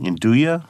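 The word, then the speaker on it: 'nduja: Tomasi does his own version of I always pronounce this wrong, Nduja? – No, it's perfect.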